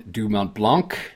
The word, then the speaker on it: du Mont Blanc: So let's talk about the ultra marathon du Mont Blanc.